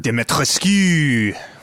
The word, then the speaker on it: Dimitrescu: Dimitrescu.